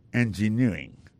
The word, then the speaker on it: engineering: Engineering, ça, l'accent tombé le même sur avant dernier syllabe là.